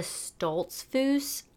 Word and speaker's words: Stoltzfus: So we're actually going to be talking about a girl named Linda Stoltzfus. I'm pretty sure that's how you say her name, only because I looked it up and listen to, like, press things of how they said her name.